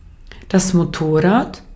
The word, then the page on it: moto: /mo.to/